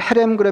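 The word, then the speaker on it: 헤렘 (חרם): 그 어, 그 진멸하는 것을 히브리어로 헤렘이라고 합니다. 이게 조금 거칠게 발음해야 되는데요. 헤렘 이렇게 발음하는데 이 그 헤렘 그러면 제일 먼저 떠오르는 게 진멸이에요.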